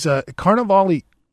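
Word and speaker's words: Carlavale: There's still discussions about treasurer, but we haven't announced candidate John Colivar of Colival. I got to learn how to spell his say his name a little Carnivale. – Better. He's Carnivale. It's C-A-R-L-A-V-A-L-E.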